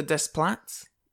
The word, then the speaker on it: Desplat: Um, Alexander Desplat is how you pronounce it.